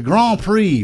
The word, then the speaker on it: Grand Prix: He said, he pronounced it instead of pre, he said it X. And Keanu said, "Yes, the Grand Prix," without correcting him, telling him he wasn't mispronouncing the word.